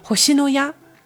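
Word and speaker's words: 星のや: 就是红西诺亚。这个红星诺亚的日语发音写成汉字其实就是星野屋，或者是叫星星的房子都可以，就是有一个小的这个双关在里面啊。那直译成中文的音译就变成红西诺亚了，一个小知识。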